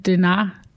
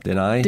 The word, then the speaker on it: Denahi: Men øh, efter at øh, Denar... – Denai.